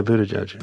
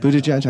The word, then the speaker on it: Buttigieg: This was a friend of mine, Danya Ruttenberg, who on Twitter was critiquing Buttigieg. – Yeah, Buttigieg. – Buttigieg. I said that right. – Yeah.